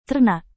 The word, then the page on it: ternak: indonesian